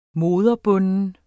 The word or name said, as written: moderbunden